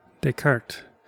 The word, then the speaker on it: Descartes: Descartes, explored the possibility that all rational thought could be made as systematic as algebra or geometry.